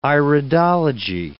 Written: iridology

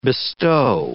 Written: bestow

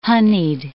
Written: honied

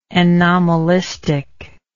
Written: anomalistically

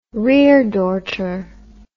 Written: reredorter

